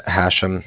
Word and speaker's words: Hashem: It's Hashem. That's H-A-S-H-E-M, as in mother at the end.